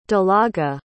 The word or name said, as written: dalaga